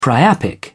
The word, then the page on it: priapic: British English